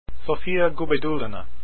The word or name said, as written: Gubaidulina